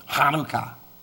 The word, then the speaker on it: Hanukkah: That's how you pronounce it, Hanukkah. Now, it's called Hanukkah for people that normally pronounce it, but that's the way you would pronounce it in Hebrew.